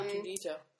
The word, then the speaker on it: Junji Ito: I probably am not pronouncing it his first name correctly, but Junji Ito. – Mm-hmm. Yeah, Junji Ito.